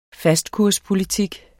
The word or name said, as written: fastkurspolitik